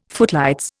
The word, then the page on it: footlights: Indian